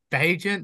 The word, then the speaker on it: Bagent: I just think, you know, you're going to Tyson Bagent.